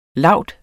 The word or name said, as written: laud